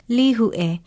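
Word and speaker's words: Lihue (Līhuʻe): Write it out with a kahako over the first vowel and an okina before the last one, then say it. Lihue. I'm Leilani Poliahu of Hawaii Public Radio, and Lihue is our Hawaiian word of the day.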